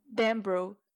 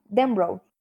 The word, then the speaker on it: Denbrough: Ele participou ainda de It, capítulo 2, em 2019, onde viveu a versão adulta de Bill Dan Denbrough. Eu falei certo? – Denbrough. – Ah, tá. Perdão, que eu sou britânica, aquelas. na ver a versão adulta de Bill Denbrough.